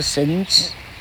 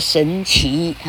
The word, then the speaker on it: 神祇: 不是念作神子，据说是念作神祇哈，老客没有查，希望没读错。啊，例如说，在 Apollo 面前，在 Hera 面前，在 Athena 面前，哈，在 Diana 面前，哈，在 Aphrodite 面前，还有谁呀？爱神 Venus 就是 Aphrodite，哈，在这些大的神祇面前呢，他吹嘘了自己的，好，他做了吹嘘的动作。